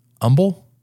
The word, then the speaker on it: Humble: And you've got wide receiver Tyrone West, 6'2", 194 star from Humble, right? – I think so. I think the H is silent.